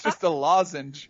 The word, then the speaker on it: lozenge: I think it's just a lozenge. – I call it really? lozenger. You do? I do, but it's, it's a lozenge, but I call it a lozenger.